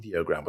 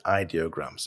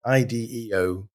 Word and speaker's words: ideogram: I pronounce it ideogram, but they say it's ideogram. So ideogram.ai.